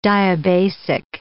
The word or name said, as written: diabasic